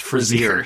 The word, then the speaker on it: Fraser: Frazier. It's pronounced Frazier.